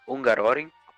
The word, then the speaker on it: Hungaroring: Circuito de Hungaroring, como aí nosso querido Rian já. – Isso, é. Como nosso, como nosso querido Rian já pronunciou da forma correta, é isso aí que ele tá falando mesmo.